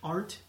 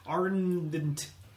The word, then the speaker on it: Arndt: Arndt. – So the D is silent. – Yeah. – Well, that makes it way easier to say, because I was trying to squeeze a D in there. – Arndt.